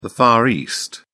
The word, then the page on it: far east: British English